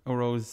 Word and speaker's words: O'Rowe: You made Mark O'Rowe's surname sound weird there. You put too much, much emphasis on the O. It sounded like Swedish or something.